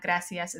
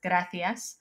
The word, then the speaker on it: gracias: If you want to say it with like a Spanish pronunciation, it's not gracias, it's gracias.